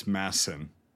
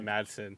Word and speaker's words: Madsen: He was in Afghanistan, and it's Massen. They don't pronounce the D. It's Massen. – Yeah, I know. But yep. I'm American, so I'm gonna say, like, Madsen.